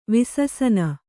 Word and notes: ವಿಸಸನ: vissana

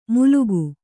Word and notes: ಮೂಲುಗು: Kannada

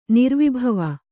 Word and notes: ನಿರ್ವಿಭವ: kannada

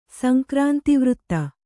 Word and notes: ಸಂಕ್ರಾಂತಿವೃತ್ತ: sankrānti vřtta

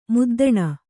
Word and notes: ಮದ್ದಾನೆ: kannada

